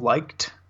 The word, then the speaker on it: Licht: Go ahead, I believe it's Daniel Licht, L-I-C-H-T.